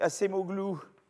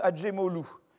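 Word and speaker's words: Acemoglu: donc, Assemoglou, alors on ne prononce pas comme ça, ça se prononce Adjemolou, parce que c'est.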